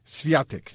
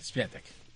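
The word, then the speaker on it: Świątek: Pero te digo una cosa, y al mismo tiempo, Iga Swiatek, así me gusta la pronunciación polaca, es la más... mi productor me dijo, es Iga Swiatek. – Swiatek.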